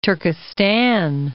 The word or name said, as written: Turkestan